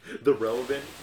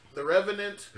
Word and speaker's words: The Revenant: The Relevant? – Can I get The Relevant? Can I get The Reverent? Can I get The Reckonant? Can I get The Relevant? The Revenant.